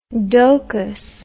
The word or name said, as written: dokus